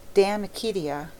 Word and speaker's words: De Amicitia: We've finished Livy and De Senectute, and now engaged with De Amicitia, pronounced Damicitia.